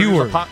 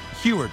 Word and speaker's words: Huard: He's on with Mike Salk and Brock Huard. I think that's his last name. I hope I didn't butcher that. But he's asked on whether Huard. – Huard, thank you.